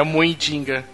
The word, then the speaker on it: Muidinga: É Muidinga.